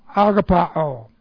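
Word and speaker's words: Agapao (ἀγαπάω): Of the verb agapao. Alright, that's A-G-A-P-A long O. Agapao.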